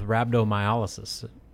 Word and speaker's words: rhabdomyolysis: And then they go into that first off-season winter conditioning workout and he gets sent to the hospital with rhabdomyolysis. Am I pronouncing that right? – Yeah, just I go rhabdo.